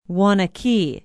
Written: waunakee

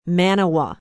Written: Manawa

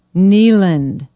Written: kneeland

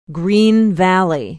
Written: green valley

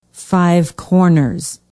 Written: Five Corners

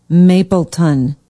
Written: mapleton

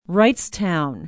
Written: wrightstown

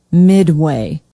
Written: Midway

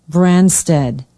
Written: branstad

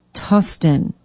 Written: tustin

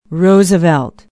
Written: Roosevelt

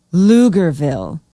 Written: lugerville

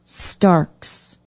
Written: starks

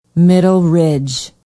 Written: middle ridge

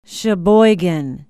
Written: Sheboygan

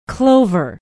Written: Clover

